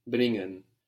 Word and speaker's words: bringen: Bringen. So you don't pronounce so much the G here because it's NG. It's an NG combination.